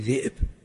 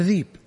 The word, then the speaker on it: ذئب: عندنا طالب من بيشة قبل 15 سنة أو أقل عندنا في، نقول له ابن أبي ذئب، عجز ينطق، يقول له ذيب، يقول له شلون ذيب، ذئب، أخذ لسانه راح الذيب جاء الذيب، والله عجز عنه ينطق ذئب،